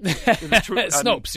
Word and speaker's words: Snopes: Or what is it, Snopes or Snoops? How do you pronounce that? Snope? – The truth site. Snopes, yeah. – Snopes.